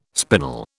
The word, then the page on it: spinel: British English